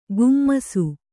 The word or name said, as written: ಕುಮ್ಮಿಸು